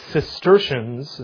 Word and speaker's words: Cistercian: Also, the Cistercians is how you say that one.